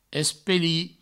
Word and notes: espelir: provençal; espeli

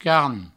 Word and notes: carn: provençal; car

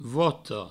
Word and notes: vota: voto

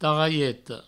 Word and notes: tarralheta: provençal; tarraieto

